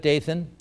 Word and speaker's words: Dathan: Today that would be a woman's name, but it's spelled with K-O-R-A-H. Another is Dathan, not Nathan, but Dathan.